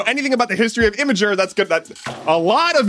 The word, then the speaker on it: Imgur: Well, now image hosting site Imgur, yes, that's how it's pronounced, is doing the same thing.